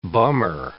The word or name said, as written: bummer